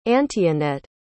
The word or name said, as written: antionette